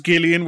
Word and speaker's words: Gillian: So that was Gillian with a hard G and Zazie with two Z's.